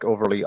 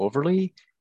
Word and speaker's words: Overlie: One is Mike Overlie Author. I've got one um, just under Michael Overlie.